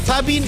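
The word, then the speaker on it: Zapping: 309 en Claro, canal 184 en Zapin. Y digo Zapin porque mi profesora castellano me dijo: pronuncie la Z.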